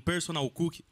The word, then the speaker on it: personal cook: você precisa de um personal cook. Eu falei certo? Agora foi.